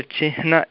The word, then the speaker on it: चिह्न: यथा चिह्नशब्दः, तत्र चिह्न इति न वदामः, चिह्नं.